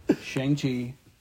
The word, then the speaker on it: Shang-Chi: Or now you bring it up, like, I always say it wrong, is it Shanghai and the Knights of the Round Table, or what is this? – Everything you said right there was wrong. Shang-Chi and the Legend of the Ten Rings.